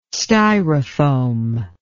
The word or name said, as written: styrofoam